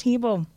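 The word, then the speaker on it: Hybl: That is correct, but also it's pronounced Hebel.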